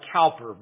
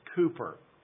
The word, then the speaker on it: Cowper: It looks like Cowper, but it's Cooper.